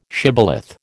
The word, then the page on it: shibboleth: American English